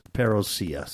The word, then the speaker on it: parousia: But it's Greek. And they're telling me it's pronounced parousia, so I'm gonna have to go with the those people parousia.